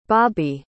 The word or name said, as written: bobbi